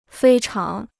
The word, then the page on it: 非常: fēi cháng